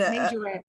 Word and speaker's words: majorette: But one college student has Twitter in uproar after creating a Margarita team at the university. Am I saying it wrong? Margarita, yes. – And Majorette.